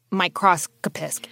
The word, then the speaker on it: microscopist: So you had to become an expert microscopist. Am I saying that right? – Yes, exactly.